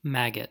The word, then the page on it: maggot: American English